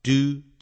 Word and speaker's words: due: A-C-C-O-U-N-T On account of the accident 后头加个 of 有时候可以用 as a result of A-S As A 不定冠词 A Result R-E S-U-L-T R-E-S-U-L-T As a result of the accident As a result 也要加个 of 也可以用 due to D-U-E、啊、 Do 加个 to 我一再讲美式英语念成 do 啊，英式英语念成 due 啊，D-U-E due to the accident，或者也可以用 owing to the accident，这些都要记起来哦。